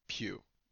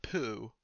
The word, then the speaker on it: Pugh: When you look up how to pronounce Florence Pugh on Google, the first result is a video of Florence Pugh telling people how to pronounce her name, and it is Pugh, not Poo, although that's tempting.